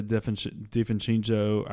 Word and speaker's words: DiVincenzo: They onto DiVincenzo. I cannot say his name. – You nailed it.